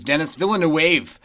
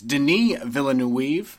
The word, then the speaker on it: Denis Villeneuve: I thought it was Denis Villeneuve. It's not right at all. It's Denis Villeneuve. – He's French. He's French Canadian.